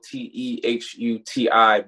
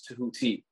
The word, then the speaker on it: Tehuti: Yeah, most people say Tehuti uh, would like, so my name is spelled T E H U T I, but people pronounce it like it's T E H U D I. Um, but so that's why I say my name is Tehuti not uh, Tehuti, but but most people say Tehuti anyway.